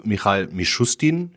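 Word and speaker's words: Michail Mischustin (Михаил Мишустин): Nun hat sich ja auch der ähm, russische Regierungschef Michail Mischustin, ich hoffe, ich habe es richtig ausgesprochen, äh, ja, ja.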